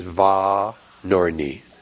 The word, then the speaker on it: Vanorny: Yeah, the last name is Von Orny. V-A-N-O-R-N-Y.